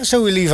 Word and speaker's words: Zoe Levi: En dan gaan we naar Zoe Levi. Zeg ik dat goed? – Ja, Zoe Levi.